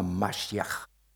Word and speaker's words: Mashiach: Der Begriff Messias, der steht im Grundtext, da steht der Name Mashiach. Das ist die hebräische Aussprache Messias ist einfach nur eine sprachliche Anpassung ans Griechische.